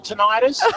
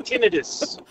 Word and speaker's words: tinnitus: I usually pronounce it tinnitus, but the doctor has corrected me, saying tinnitus.